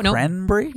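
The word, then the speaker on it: Cranbury: Is it spelled like cranberry, but you say Cranberry? – No, B-U-R-Y, Cranberry, because it's near Danbury and Southbury.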